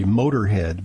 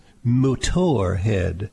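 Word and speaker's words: Motörhead: It's actually, I mean, you would pronounce it ordinarily Motorhead, but it's got a little umlaut over the O, so aficionados refer to it as Motorhead.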